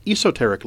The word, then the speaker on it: Esoteric: Originally recorded in 1963 and released that year as an LP on Warner Brothers, in 2011, the folks at the British Esoteric, or as they pronounce it, Esoteric label, reissued this classic material on compact disc.